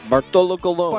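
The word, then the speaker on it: Bartolo Colón: You didn't see how do you say his name? – Bartolo Colon.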